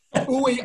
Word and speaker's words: Uiagalelei: So, Uiagalelei.